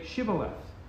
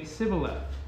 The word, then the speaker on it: Shibboleth: And if he said no, then they would say to him, "Then say Shibboleth." He would say Sibboleth, for he could not pronounce it right.